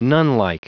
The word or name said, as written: nunlike